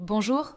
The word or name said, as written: bonjour